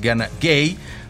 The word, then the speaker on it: Gana Gueye: Lamine Camara, porque también tiene su Lamine allá en Senegal, está en la media cancha junto con Gana Gueye, quiero decir, la pronunciación es Gana Gueye.